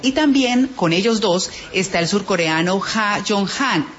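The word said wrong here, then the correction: Ha-Joon Chang: Shang es el apellido, Ha Jong-shang.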